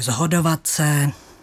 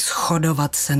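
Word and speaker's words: shodovat se: Moravská výslovnost upřednostňuje tu znělou podobu, takže zhodovat se. – Napadá mě zhrabovat. – Tak a všechna podobná slova. A ku podivu se v příručkách píše, že stejně spisovně lze říct i schodovat se nebo schrabovat.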